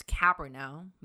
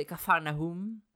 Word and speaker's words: Capernaum: So for this episode, we're going to talk about, in English pronunciation, it's Capernaum, but it's actually Capernaum.